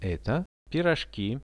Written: eto piroshki

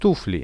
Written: tufli

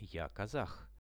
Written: ja kazah

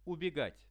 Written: ubegat

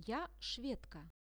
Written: ja shwedka

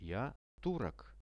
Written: ja turok

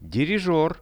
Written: dirizher

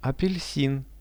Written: apelsin

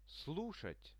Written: slushat

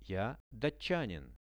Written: ja datchanin

